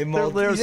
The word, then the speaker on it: Maldives: And he was the president of, and I always get this wrong, is it Malvides? – Maldives. – He was the ex-president of Maldives.